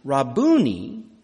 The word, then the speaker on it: Rabboni: He does not use the short form, Rabbi, but he takes the time for another syllable, the long pronunciation, Rabboni, which more literally means, "My Lord, my Master."